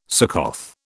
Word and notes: Succoth: British English

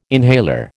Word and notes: inhaler: American English

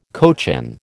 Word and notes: cochin: American English

